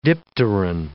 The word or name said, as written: dipteran